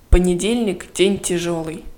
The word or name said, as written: понедельник день тяжелый